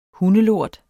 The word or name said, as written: hundelort